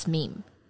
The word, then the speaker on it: meme: 对，《自私的基因》那本书里面，其实那个作者有说，就是 meme 这个字呢，他比较希望大家的念法是 rhymes with cream，就是跟 cream 一样押韵，so it's meme。